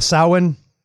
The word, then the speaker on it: Samhain: which is so familiar to Samhain, or as Joel correctly pronounces it, Samhain.